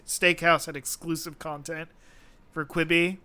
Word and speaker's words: Quibi: So would Quibi still be with us to get today if they had if it'd been clear that it was supposed to rhyme with ribeye?